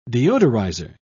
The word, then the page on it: deodorizer: British English